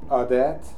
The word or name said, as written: Haidia